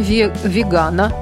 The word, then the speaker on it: вегана: 1 ноября, среда, Международный день вегана. Ударение официально закреплено именно на «а». Вы удивитесь, но это так.